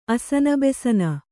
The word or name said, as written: ಅಸನಬೆಸನ